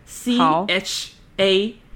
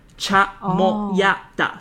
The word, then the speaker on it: chamoyada: 好啊，我们也等一下放在叙述，我可以先口拼给大家听，还是 CHAMOYADA CHAMOYADA、所以它其实就是它怎么念它就是怎么拼啊， CHA CHAMOYADA、 MOYADA，所以其实还蛮好记的。